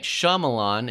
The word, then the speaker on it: Shyamalan: Night Shyamalan is his name,